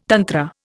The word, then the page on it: tantra: Indian